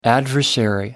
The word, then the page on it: adversary: American English